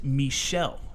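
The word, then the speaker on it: Michel: So he joins, and by the way, it's pronounced Michelle.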